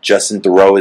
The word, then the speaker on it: Justin Theroux: And the Justin Theroux is in it also, is that how you say his name? – Yeah.